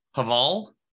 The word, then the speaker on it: Haval: If we talk about the big, so this is Haval? – Correct.